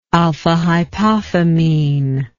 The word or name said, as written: alphahypophamine